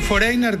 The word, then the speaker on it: Foreigner: yo pronuncié foreigner como si fuera en yiddish, pero foreigner es extranjero. – Extranjero. – En inglés, ¿cómo lo pronuncias en inglés? ¿O? – Foreigner. – Foreigner.